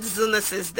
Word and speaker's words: zoonosis: And so we go into what we have for today, and we are talking Zoonosis is Rising, Shared Responsibility, Shared Space. But let me start from here. Please, what is zoonosis? And why is July 6th marked as World Zoonosis Day? – Thank you very much.